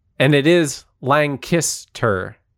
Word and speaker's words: Lancaster: Didn't Lancaster put out shirts with the phonetic pronunciation on it? – Yes, it was like Lang and then Kiss, K-I-S-S, and then Tur.